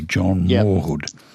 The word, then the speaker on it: Mawhood: Now, I'm going to spell that because it's an interesting name. M-A-W-H-O-O-D, John Morwood. – John Morwood, yeah, that's correct.